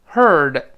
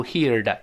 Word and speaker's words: heard: Heard 就是刚才 hear 一般过去式。” 在 here 后面加一个字母 d，注意不要读 heard，要读成 heard，heard that，就刚才那个事儿。